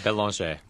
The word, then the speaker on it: Belanger: Is it Belanger or Belanger? – Belanger. – Belanger. – As he is proud to say, he's a fighting Frenchman.